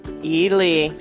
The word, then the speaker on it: Ely: Ely.